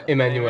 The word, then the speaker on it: Emmanuel: Emmanuel.